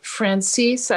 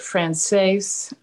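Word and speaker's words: Franzese: And it's the it's the life story of Sonny Franzini, or uh, I'm sorry, I said that wrong, Franzisi. – Well, it, I pronounce it Francis. I've heard it Frances. Uh, he pronounced it Francis or Frances.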